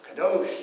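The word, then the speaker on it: Kadosh (קדוש): Kadosh. Yes, who said that? everybody said that. yeah, know, know this one. Okay, kadosh. Yeah, that's the Hebrew word for holy, right?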